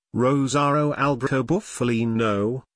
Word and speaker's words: Rosario Alberto Bufalino: Russell Bufalino, born Rosario Alberto Bufalino, Italian pronunciation, Rosaro Alberto Bufalino,